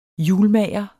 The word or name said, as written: hjulmager